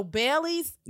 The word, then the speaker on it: Baleigh: So Bailey's name is spelled B-A-L-E-I-G-H. So it is like Raleigh, Durham, like Raleigh, North Carolina. – Uh-huh. Yeah. Mm-hmm. – But with a B, right? – Yeah.